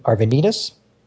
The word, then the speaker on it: Arvanitis: But the person who did give the presentation that night was someone named Andy Arvanitis. And I did check the pronunciation of his name with him, so I hope I got it right. He is the creator of the Aero language, E-E-R-O.